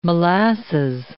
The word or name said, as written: molasses